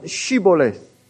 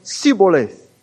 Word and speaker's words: Shibboleth: One word that some of the Jews could not pronounce is the word Shibboleth. Some pronounce it as Sibboleth.